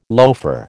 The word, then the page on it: loafer: American English